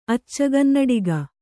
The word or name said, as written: ಅಚ್ಚಗನ್ನಡಿಗ